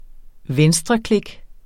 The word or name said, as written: venstreklik